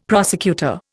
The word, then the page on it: prosecutor: Indian